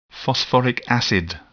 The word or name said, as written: phosphoric acid